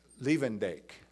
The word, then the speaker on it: Levendijk: Now, I hope there are no Dutch speakers here, because I'm not going to pronounce the author's last name properly, but it's Joris Levendijk, who wrote... Levendijk. Okay. Thank you.